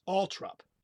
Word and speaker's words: Althorp: It's in a British English, it's Althorp. – Althorp. – Which isn't that bad. It's not as bad as some of the others, actually. – No. But it's spelled Althorp, T-H-O-R-P. – Right. – And it's Althorp. – And it's pronounced Althorp. Which really, I'm going to throw in something here before we keep going.